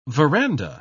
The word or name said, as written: veranda